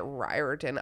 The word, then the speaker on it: Riordan: He pronounces it Riordan. I don't really care that much. He doesn't. – I just did that. – You said Riordan. – Yeah, that's what I did. – Yeah. You did it right. – Oh, that's right. – Yeah. – Oh. – It rhymes with fire truck is like what they say to me.